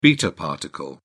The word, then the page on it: beta particle: British English